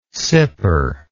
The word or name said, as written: sipper